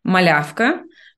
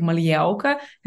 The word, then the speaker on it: Malyauka: My name is, if I will say like in a more Belarusian way, it would be like Malyauka. Yeah, but I'm used to like this way, because, like when I'm traveling around and I'm living in the Netherlands, my name always pronounced so weirdly. And sometimes I even like can say like when people ask my second name, I can say like it's in a more Dutch way, like Malyauka. And they're like, why are you saying it?